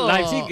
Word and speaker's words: Leipzig: Es Leipzig, es lo que nos dijeron que que teníamos que decir.